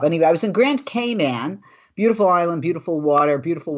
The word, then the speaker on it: Cayman: And speaking of welcome back, I just was in the Cayman Islands on the island of Grand. I'm saying it wrong. You know, it's not Cayman. Did you know it's Cayman? Like, hey, man.